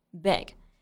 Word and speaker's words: big: 我们在读这句话的时候呢，要注意 big 这个单词的发音，中间是发短元音 a 的这个音。我们在发这个音的时候，下巴会掉下去一点点，面部处于比较放松的状态。 big, 千万不要发成了长元音 e 的音，读成 big 就不对了，应该是 big。